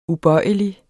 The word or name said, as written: ubøjelig